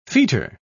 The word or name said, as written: fetor